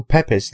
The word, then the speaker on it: Pepys: During the Great Fire of London, Samuel Pepys, that wrote Pepys, is that how it's properly pronounced? I'm never sure.